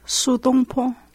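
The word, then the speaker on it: Su Dongpo: Su Dong Po? – Su Dong Po.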